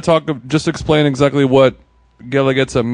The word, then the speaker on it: Guelaguetza: Guelaguetza. – Okay, that's good. – It's I always tell people when they're reading it, the U is silent, and that helps everybody. – Yeah. – Okay. Guelaguetza.